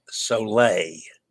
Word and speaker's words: Soleil: Dennis says Soleil. Okay. I did say that wrong. Cirque du Soleil. I know that one too.